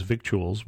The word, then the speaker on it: victuals: And that's the, I mean, basically the word itself says that it's, we have a word in English that is victuals. We don't use it that much, except I don't think the C is pronounced, but edible things.